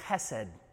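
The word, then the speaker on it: chesed (חסד): It's the hard H in Hebrew that you gotta love. So try, repeat after me chesed. There you go. If you don't taste your breakfast, you're not saying it right, right?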